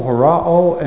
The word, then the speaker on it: horao: But and the word for seeing here is horao. We get our word horizon from it. We've come across it before. H-O-R-A-O.